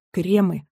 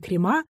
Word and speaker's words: кремы: Попутно заметим, что во множественном числе слово «крем» произносится как «кремы», но никак не «крема», что нередко услышишь и в речи, и в эфире.